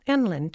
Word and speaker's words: NLE: Enlin, to rhyme with gremlin, right?